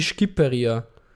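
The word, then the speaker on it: Shqipëria: Ah, es klingt ungefähr wie Skipperia. Okay, weil da sagt man kein S, aber das ja vielleicht nicht immer S, ja, Skipperia.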